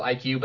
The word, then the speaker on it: EyeQ: IQ, but it's spelled E Y E Q, not like the intelligence, but like ICU. The I to C.